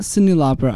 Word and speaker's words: Cyndi Lauper: And also the last two artists we played, not Cyndi Lauper. I apologize. Cyndi Lauper. I apologize. I mispronounced her name completely.